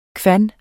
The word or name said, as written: kvan